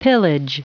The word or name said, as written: pillage